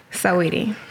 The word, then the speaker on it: Saweetie: Saweetie.